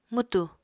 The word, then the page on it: mwtù: Apatani